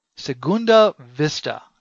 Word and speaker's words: Segunda Vista: Guitar player Ryan Mars, and he says that's the way you pronounce his name is Mar, but it's spelled M-E-A-G-H-E-R. His album called Lost Days. And Aaron Araneta and the Brent Fisher Orchestra, Segunda Vista. Now, I know that Mike's going to correct me on this pronunciation, but... – No, it's good. Well done. Well done.